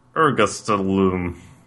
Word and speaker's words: Ergastulum: Ergastulum.